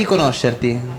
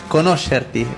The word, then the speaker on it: conoscerti: Piacere di conoscerti. – Piacere de conocerti, conoscerti. Conoscerti. – Sí, perfecto.